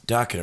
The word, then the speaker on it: Dokken: Dokken, it rhymes with rockin'.